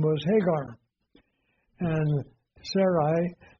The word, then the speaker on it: Sarai: And the word, it would actually be pronounced Sarai in Hebrew.